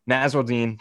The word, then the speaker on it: Nasirildeen: Pronounce his name for me. – Nasirildeen. Florida State safety.